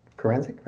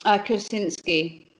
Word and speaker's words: Krasinski: Did John, we're talking about John Kurencic, right? – Krasinski.